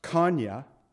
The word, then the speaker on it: Coniah: So Jehoiachin, he was also called Jeconiah, or we might say Jeconiah, American, or Coniah, which would really be pronounced Kanya.